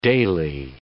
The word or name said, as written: daily